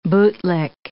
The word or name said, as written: bootlick